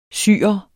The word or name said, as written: syrer